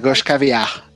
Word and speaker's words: gauche caviar: que es el gauche caviar, que no lo sé pronunciar, Lo La gauche caviar.